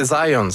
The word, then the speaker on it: Zajonc: Non, c'est Zajonc. – Oui? OK. – Ouais, j'ai-tu pas dit Zajonc? – Ben, j'avais dit plus Zajonc, mais... – Ah, ben, j'ai prononcé un petit peu à l'anglais. J'ai prononcé mon polonais à l'anglais.